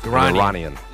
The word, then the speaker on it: Iranian: Get it pronounced correctly. – Yeah. Iran. – Iran. – Iranian. Yes. – Not Iran and Iranian.